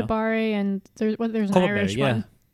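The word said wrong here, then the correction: Kopparberg: Yeah, Copaberry, exactly. How you say it? – Copaberg. Exactly. You've been there.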